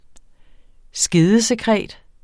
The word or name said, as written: skedesekret